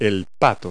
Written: duck

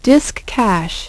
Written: disk cache

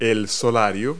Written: sundeck

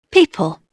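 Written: people

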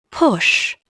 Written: push

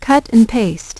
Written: cut and paste

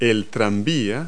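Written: streetcar

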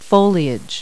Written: foliage

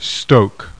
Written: STOKE

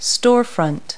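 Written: storefront

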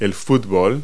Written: soccer

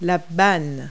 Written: awning

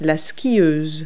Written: skier

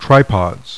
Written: tripods

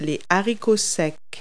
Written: dry bean

